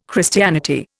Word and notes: Christianity: Indian

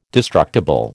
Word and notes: destructible: American English

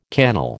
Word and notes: cannel: American English

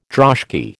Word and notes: droshky: American English

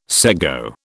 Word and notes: sego: British English

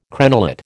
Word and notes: crenellate: American English

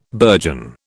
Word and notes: burgeon: British English

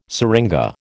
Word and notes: syringa: American English

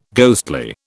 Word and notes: ghostly: British English

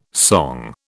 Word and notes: song: British English

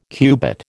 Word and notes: cubit: American English

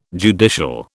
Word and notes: judicial: British English

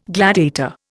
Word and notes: gladiator: Indian

